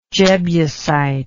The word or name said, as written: jebusite